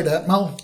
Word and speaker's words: Atmel: Am I saying that right, Atmel? – Yes, you are.